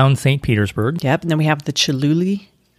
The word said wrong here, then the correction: Chihuly: Did I say that right? Chihuly. Chihuly. Yes, we have one of those.